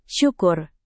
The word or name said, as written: syukur